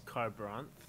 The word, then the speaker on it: Kharbranth: So it is said as Carbranth, and I would spell it as Kharbranth. Going with car, kind of like colon, the KH. That's where I'm going with the KH at the beginning.